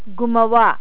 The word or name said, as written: gumawa